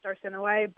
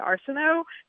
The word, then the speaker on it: Arsinoe: it's actually pronounced Arsinoe, but I think everybody else who reads it will say Arsinoe, sort of like the way I used to pronounce Hermione, Hermoine, until the Harry Potter movies came out.